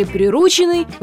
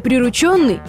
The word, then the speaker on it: прирученный: Если вы мысленно сказали «прирученный», вынуждена вас разочаровать. На самом деле причастие «прирученный» звучит именно так, а ударение на «у» является грубой ошибкой.